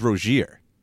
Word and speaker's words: Rozier: it's Rozier. That's how all these guys have always been pronounced.